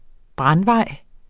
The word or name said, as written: brandvej